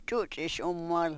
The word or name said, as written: chuchish u-mol